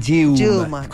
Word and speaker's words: Dilma: Los brasileños le dicen Dilma. Con U. Dilma.